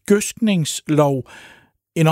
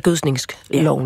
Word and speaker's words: gødskningsloven: Jamen, hvad er så den korrekte udtale af ordet gødskningsloven? Gødskningsloven. Ja, det har vi spurgt sprogforsker Michael Ejstrup om. – Hvad hedder det? Gødskningsloven eller gødskningsloven? Jeg vil sige, at der er lidt frit valg,